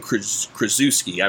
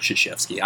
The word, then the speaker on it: Krzyzewski: Colquitt County quarterback Stephen Krzyzewski, I believe is I'm saying it correctly. It's like Mike Krzyzewski. I don't know. It's Polish.